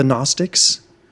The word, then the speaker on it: Gnostic: Okay, Gnostic is spelled with a G, but it's silent. And then when you put the A before it, the G becomes a hard sound. So you've got Gnosticism and agnosticism.